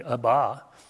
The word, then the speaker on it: Abba: In the Aramaic language, that word Father is Abba. And I think in the Hebrew, it would be pronounced with the accent differently, Abba.